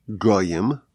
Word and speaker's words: goyim: So the word is pronounced as goyim, nations, but the word is spelled as geim, proud ones.